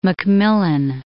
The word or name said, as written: Macmillan